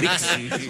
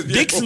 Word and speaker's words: Disc: Compact Discs. No, Dix no. D-I-X no es. Es D-I-S-C. Disc.